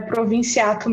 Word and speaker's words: Provinciato: Bom, a forma como eu tenho falado desde criancinha, né, que os meus pais me ensinaram, é Provinciato mesmo, né, o jeito abrasileirado de falar.